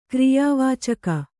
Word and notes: ಕ್ರಿಯಾವಾಚಕ: kriyā vācaka

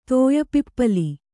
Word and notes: ತೋಯಿಪಿಪ್ಪಲು: tōyi pippalu

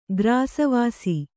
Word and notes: ಗ್ರಾಸವಾಸಿ: Kannada

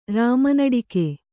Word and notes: ರಾಮನಡಿಕೆ: Kannada

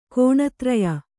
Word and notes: ಗುಣತ್ರಯ: guṇatraya; kannada

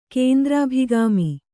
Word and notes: ಕೇಂದ್ರಾಭಿಗಾಮಿ: kēndrābhigāmi; Kannada

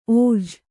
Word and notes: ಓಝ: ōjha

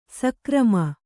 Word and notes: ಸಕರ್ಮ: sakarma

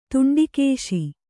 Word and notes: ತುಂಡಿಕೇಶಿ: Kannada

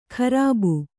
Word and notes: ಕರ್ಬು: karbu

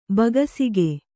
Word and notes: ಭಕ್ಷಕ: bhakṣaka

